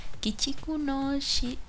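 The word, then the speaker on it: Kichiku no Shikake Saiku: Y, fíjate, lo dije mal. ya va. Kichiku no shi.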